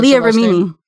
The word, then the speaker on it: Leah Remini: Leah Remini or Leah Remini? I don't know how to pronounce Leah Remini.